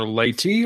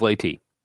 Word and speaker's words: Leyte: Oh, okay, so apparently it is pronounced most commonly either Leyte or Leyte. – I'll go with Leyte.